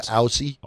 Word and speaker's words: Aussie: I'm saying Aussie. – Aussie. – Aussie. – Aussie. – Yeah, even if there's two S's there, like, you got to break the rules for American pronunciations.